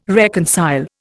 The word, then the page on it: reconcile: Indian